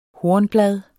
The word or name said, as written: hornblad